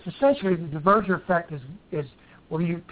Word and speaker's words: Duverger effect: the Duverger effect? – Duverger effect, yeah.